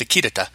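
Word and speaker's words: Akitada: The Sugawara Akitara, or Akitara, I believe it's pronounced Akitara, series by I.J.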